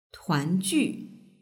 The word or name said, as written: tuanju